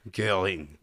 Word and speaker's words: Göring: Göring.